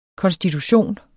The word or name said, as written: konstitution